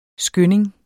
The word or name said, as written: skynding